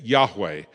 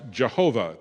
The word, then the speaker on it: Yahweh: And it's pronounced, we think, Yahweh. Uh, traditionally, it's pronounced Jehovah.